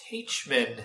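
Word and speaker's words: Taichman: I am Ken Davenport, and my guest today is the Tony Award-winning director, Rebecca Teichman.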